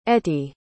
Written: etty

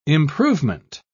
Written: improvement

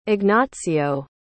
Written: Ignazio

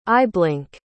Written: eyeblink

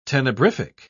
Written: tenebrific

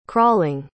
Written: crawling

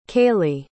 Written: kaylee

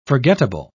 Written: forgettable

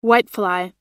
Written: whitefly

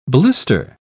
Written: blister

